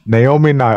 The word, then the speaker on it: Naomi Nye: Naomi Nye.